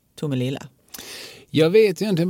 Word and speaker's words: Tomelilla: Och eh, hävdade ju då med någon sorts liksom, historikerns rätt att alltså, Tomelilla är det korrekta uttalet.